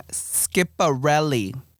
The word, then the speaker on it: Schiaparelli: His name, they pronounce their name Schiaparelli.